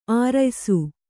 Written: ಅರೆಯಿಸು

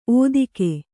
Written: ಒದಗೆ

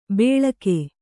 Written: ಬೇಳಕೆ

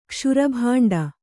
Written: ಕ್ಷುರಭಾಂಡ

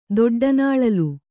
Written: ದೊಡ್ಡನಾಳಲು

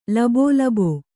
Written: ಲಬೋಲಬೋ

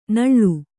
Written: ನಾಳು

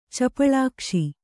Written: ಚಪಳಾಕ್ಷಿ

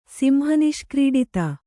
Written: ಸಿಂಹನಿಷ್ಕ್ರೀಡಿತ

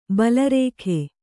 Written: ಬಾಳಾರ್ಕ

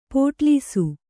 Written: ಪೋಟಲೀಸು

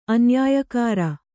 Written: ಅನ್ಯಾಯಕಾರ

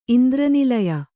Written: ಇಂದ್ರನಿಲಯ

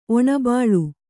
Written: ಒಣಬಾಳು

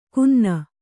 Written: ಕುನ್ನ

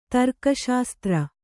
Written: ತರ್ಕಶಾಸ್ತ್ರ

